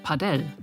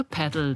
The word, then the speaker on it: Padel: Daher wird der Name auch manchmal spanisch ausgesprochen, Padel. Inzwischen hat sich bei den 300.000 Menschen, die es in Deutschland spielen, aber die englische Variante Padel durchgesetzt.